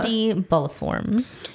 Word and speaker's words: infundibuliform: Infundibuliform. – Can you guys spell that? – I-N-F-U-N-D-I. B-U-L-I-F-O-R-M. – L-I-I-F-O-R-U-M.